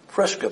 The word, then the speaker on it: Prisca: Prisca, P-R-I-S-C-A, which is a derivative, or Priscilla is a derivative of Prisca.